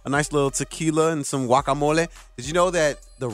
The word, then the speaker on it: guacamole: If you want to say it properly, don't put the hard G on there. Like a W, guacamole.